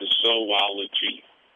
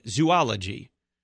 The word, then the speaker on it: zoology: In no particular order, someone let Todd know that the scientific study of animals is pronounced zoology. There are two O's, and they are part of two different syllables.